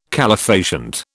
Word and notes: calefacient: British English

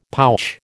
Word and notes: pouch: American English

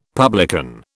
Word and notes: publican: British English

